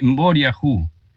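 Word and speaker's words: mboriahu: Se pronuncia con P, pero en realidad es con MB, poriajú.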